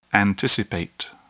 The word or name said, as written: anticipate